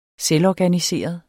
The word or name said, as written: selvorganiseret